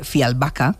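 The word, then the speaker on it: Fjällbacka: Es verdad que sus novelas parecen guiones, Pues resulta que los crímenes de Fialbaca, que es como se pronuncia,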